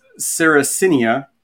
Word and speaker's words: Sarracenia: But it's Sarracenia is the genus name, and Purpurea is the species name.